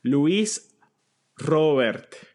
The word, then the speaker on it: Luis Robert: I'm going to give you the Spanish pronunciation. Are you ready for it? – Yes. – Okay. Luis Robert.